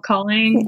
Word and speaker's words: Kaling: Kaling.